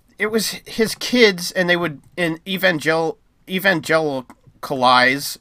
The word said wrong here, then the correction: evangelize: Is that how you say it? Evangelize. There we go.